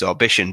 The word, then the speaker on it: Orbition: And to me, like Orbition, you know, I look at it and it just reads Orbition, but it's funny because every person that reads it fucking pronounces it wrong.